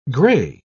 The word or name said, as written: gray